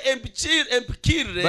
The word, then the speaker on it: empikire: Am I pronouncing it properly? – Njogedde bulungi. – Empikire. – Empikire. – So I corrected them. – Kati nemba golola. – They should say empikire.